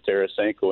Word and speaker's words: Tarasenko: It becomes somewhat intriguing because of the injury scenario of Tarasenko, and that you said that right.